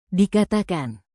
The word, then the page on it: dikatakan: indonesian